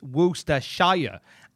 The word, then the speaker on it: Worcestershire: I was I did a Twitch stream and I mentioned Worcestershire sauce, and somebody in the thing wrote it phonetically saying, actually, it's Worcestershire.